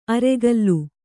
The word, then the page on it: ಅರೆಗಲ್ಲು: aregallu